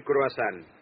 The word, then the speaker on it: croissant: A Francia usted no sabe la grandeza del croissant.